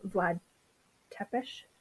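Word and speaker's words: Vlad Țepeș: She's often tied to Vlad Tepes.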